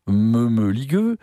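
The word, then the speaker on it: mămăligă: Et dans Mamaliga, pour le prononcer à la roumaine, me-me-ligueux,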